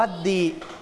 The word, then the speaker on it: the: การที่ตัว A เนี่ยอยู่ตามหลังคำว่า the เนี่ยเราจะไม่ออกเสียงว่า the นะครับนักเรียนปลายทางจำไว้ด้วยนะครับเราจะออกเสียงว่าดี